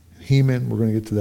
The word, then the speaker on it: Heman: So the singers, and here's one of those names, spelled H-E-M-A-N. Ray Hughes pronounced this as He-Man.